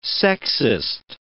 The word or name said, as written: sexist